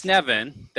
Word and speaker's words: Glasnevin: Glasnevin, that's how we decided we're going to pronounce it, Glasnevin Cemetery in Dublin.